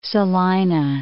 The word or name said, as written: Salina